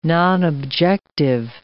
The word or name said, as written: nonobjective